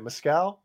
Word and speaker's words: mezcal: Mezcal mix? – Mezcal.